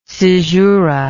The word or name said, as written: caesuric